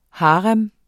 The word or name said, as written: HAREMMER